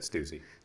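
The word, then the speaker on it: Stüssy: Stussy, is that how you say it? – Stussy.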